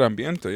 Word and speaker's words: Ambiente: Ahora, and I believe you pronounce it Ambiente. – Yeah, this one just said Ambiente, yeah.